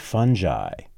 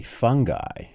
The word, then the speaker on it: fungi: It's called Fantastic Fungi, or an alternate pronunciation would be fungi. In fact, it's pronounced that way a couple of times in the film, but the overall consensus, it would seem, is fungi.